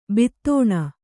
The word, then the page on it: ಬಿತ್ತಾಣ: bittāṇa